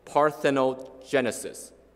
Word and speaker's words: parthenogenesis: Well, in the scientific world, the scientific term for what we call the virgin birth is called parthenogenesis.